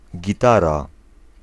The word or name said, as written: guitar